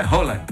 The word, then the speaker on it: Haaland: Na realidade, a pronúncia é Haaland.